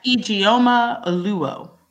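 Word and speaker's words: Ijeoma Oluo: Yes, Ijeoma Oluwo.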